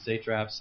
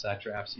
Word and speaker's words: satraps: So Darius appointed 120 satraps, and by the way, I looked that word up because I didn't know how to pronounce it either, and it could go both ways, satraps, satraps, either way.